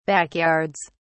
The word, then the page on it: backyards: British English